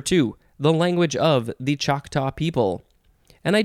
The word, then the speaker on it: Choctaw: Um, this, well, so it says it's from their own language, their word, C A H T A. So I guess that would sort of be how it's spelled, but it's pronounced Choctaw.